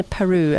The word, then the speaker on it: Peyroux: Now, Tristan, that music is from a fantastic lady called Madeleine Peru, and it's spelled P-E-Y-R-O-U-X.